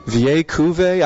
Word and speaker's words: Vieille Cuvée: Vieille Cuvée? I don't know how to spell it. That's what Lars taught me. That they explained to me that's how you pronounce it.